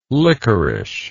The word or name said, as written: liquorice